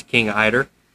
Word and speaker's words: king eider: I didn't know how to pronounce that up until like 2 days ago. I look at the word and it doesn't look right, but it's king eider.